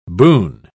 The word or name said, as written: boon